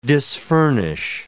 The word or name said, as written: disfurnish